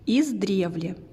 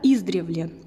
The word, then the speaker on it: издревле: Однако многие говорят «издревле» с ударением на первый слог. Видимо, по аналогии с синонимом «издавна», Действительно, слова похожи и по своему составу, и по смыслу, но в нашем наречии надо ориентироваться на родственников и ставить ударение на корень, особенно учитывая тот факт, что оно было таким и 200 лет назад.